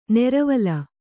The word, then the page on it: ನೆರವಲ: neravala